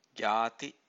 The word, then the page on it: jati: Pali